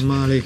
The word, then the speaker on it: Мали: Не знам къде е точното ударение, ти може да е Мали, ти ще ме поправиш. – Мали, като ги гледам.